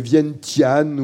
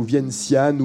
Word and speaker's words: Vientiane: Alors, différentes prononciations sont possibles, sont acceptées. Vientiane ou Vientiane ou Vientiane. Euh, si on prononce à la manière asiatique, sans doute, est-ce un peu différent. En français, on écrit le nom de cette capitale V-I-E-N-T-I-A-N-E, e, capitale du Laos.